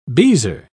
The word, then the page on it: beezer: British English